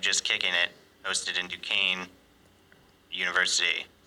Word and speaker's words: Duquesne: It's pronounced Duquesne. Continue. – You must have some sort of feed. I'm saying it correctly. It said Duquesne's. – Right. Yeah, the phone adds an S.